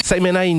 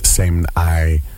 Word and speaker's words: Saimn-I: This is, I believe, pronounced Saimn-I, a voice from Belgium, a voice that I've come across playing with Vibronics.